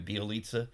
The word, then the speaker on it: Bjelica: Bjelica.